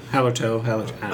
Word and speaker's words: Hallertau: Hallertau. Is that how do I say it? – Hallertau.